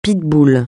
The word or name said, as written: pitbull